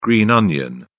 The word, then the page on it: green onion: British English